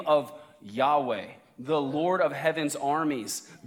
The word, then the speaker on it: Yahweh: You might have grown up in church hearing it pronounced Jehovah. And now most Bible scholars say that's probably not right. That's actually probably a German pronunciation of the Latin transliteration of YHWH. Probably the most accurate translation of God's name or pronunciation, I should say, is Yahweh or something like that.